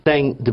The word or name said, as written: tem